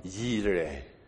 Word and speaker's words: Yireh: And one you've heard of is Jehovah Jireh. That is the Greek pronunciation. But in the Hebrew, it is Jehovah Yireh.